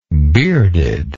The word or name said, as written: beardedness